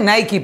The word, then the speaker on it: Nike: Ya, primero no se dice Nike, ajá. Se dice Nike, papi.